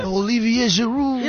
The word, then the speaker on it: Olivier Giroud: Olivier Giroud. – Is it? – Yeah. That's how you pronounce it.